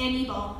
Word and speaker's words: Anibal: It's like animal, but Anibal. I don't know. It's pronounced weird. – Anibal. – And then that's Amy, but her name is spelled E-M-Y-I.